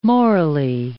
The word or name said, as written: morally